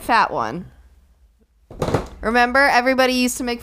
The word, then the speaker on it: Fatone: It's spelled fat one, and it's but it's called Fatone, and that's horrible.